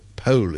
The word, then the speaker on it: Powell: particularly Charles Powell, who was her, or Pole, as if you're very posh, is how you pronounce it, apparently,